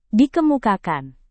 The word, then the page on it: dikemukakan: Indonesian